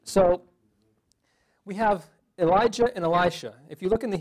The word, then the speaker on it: Elisha: I have a niece named Elisha. That's how it's pronounced.